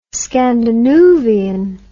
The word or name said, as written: scandinoovian